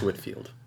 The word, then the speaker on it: Whitefield: George Whitefield, spelled Whitefield. – Mm-hmm. – And, pronounced Whitefield. – Huh? – Pronounced Whitefield.